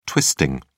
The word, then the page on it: twisting: American English